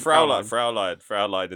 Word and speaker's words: Fräulein: But I did meet a lovely Fräulein. Is that how you say it? – Fräulein in the airport.